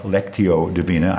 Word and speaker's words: Lectio Divina: So we have talked about Lectio Divina. I finally got to pronounce it right. I was pronouncing it like Lexus, not a car.